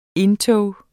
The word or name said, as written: indtog